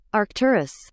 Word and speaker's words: Arcturus: I don't even know how to pronounce this. Well, let's see. Let's see how Google pronounces it. Hold on 1 second here. Put it in there. – Arcturus.